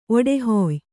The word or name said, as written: ಒಡಹಾಯ್